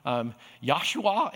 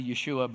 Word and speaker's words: Yeshua: One guy came and asked me a question whether or not I knew Yahshua, and I said, well, it's actually a Yeshua, but I wasn't going to correct his pronunciation at the time.